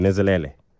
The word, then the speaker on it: Nenzhelele: Nenzhelele.